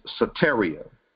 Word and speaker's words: soteria: The Greek definition of salvation, if you write this down, if you're following, is soteria. And that's spelled S-O-T-E-R-I-A. Soteria. S-O-T-E-R-I-A.